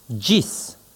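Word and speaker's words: ĝis: G circonflexe se prononce dj, comme dans adjoint. Exemple, gis, jusqu'à.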